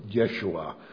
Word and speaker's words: Yeshua: Why was Jesus named Jesus, or Yeshua, as it was most likely pronounced in Hebrew?